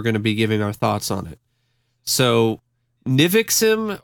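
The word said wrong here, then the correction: NXIVM: Nexium. – Nexium had all the hallmarks of a money scamming cult, but the truth was far, far worse.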